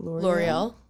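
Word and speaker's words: L'Oréal: So there's this like TikToker and she does like makeup videos, and she was promoting for L'Oreal. How do you say it? – L'Oreal.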